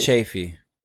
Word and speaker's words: Chaffey: I say Daniel Chaffey.